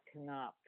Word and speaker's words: Knopf: Well, according to this, is it Knopf? – Yeah, Knopf.